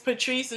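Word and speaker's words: Patrese: Okay, and my name is Patrice Knowles, so it's, and my Patrice is not spelled normal, it's P-A-T-R-E-S-E, Knowles.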